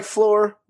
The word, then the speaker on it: Flore: That's lore with an F at the beginning. So it sounds like floor, but it's not the floor you're thinking, because it's our floor.